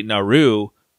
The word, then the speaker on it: Naru: I did learn that, like, the way her name is spelled, N-A-R-U, you would think you'd say Naru, but in Comanche, the R is pronounced like a D. Like, if you listen even in the English, you hear him say Naru instead of...